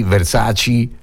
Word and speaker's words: Versace: Scorsese, la E finale si legge I, Scorsese, Versace, lo so, è così, che ci possiamo fare.